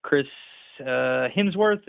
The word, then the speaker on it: Chris Hemsworth: He looks like the guy who plays Thor in the Marvel movies, Chris Hemsworth. Am I saying that right? – That's correct.